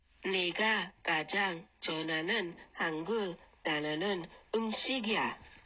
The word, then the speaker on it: 좋아하는: And I think the bit to improve in your sentence was 좋아하는. 좋아하는. 좋아. Is a 히읗 받침 and then an ah sound as a second syllable.